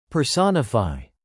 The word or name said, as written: personify